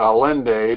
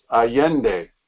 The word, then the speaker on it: Allende: We pronounce it Allende, but it's Allende, who promptly began to impose a Marxist dictatorship on Chile.